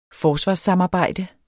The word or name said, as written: forsvarssamarbejde